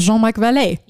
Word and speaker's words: Jean-Marc Vallée: Came out in 2014, directed by our favorite Jean-Marc Vallée. – Jean-Marc Vallée. We don't know how to pronounce his name. – He's only, that is how you pronounce it, is Jean-Marc Vallée.